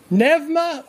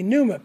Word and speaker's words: pneuma: Well, you can pretty easily, the word there is pneuma, or it would be the Greek pronunciation, or if you anglicize it, it'd be pneuma, P-N-E-U-M-A, okay?